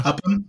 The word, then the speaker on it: Upham: Upham. It's hard to say his name because there's a secret H in there.